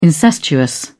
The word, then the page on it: incestuous: British English